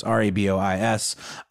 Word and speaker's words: Rabois: Forgive me if I'm mispronouncing that. It's Rabois.